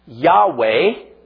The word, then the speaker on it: Yahweh: Yahweh, Yahweh, it's a German W. Wiener Schnitzel, not Wiener Schnitzel, Wiener Schnitzel. So the way you really pronounce it is Yahweh, Yahweh.